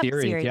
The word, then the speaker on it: Ceres: Let's see, USAID, Ceres. Did I pronounce that? Ceres? Is it Ceres? Yep, Ceres. Yeah.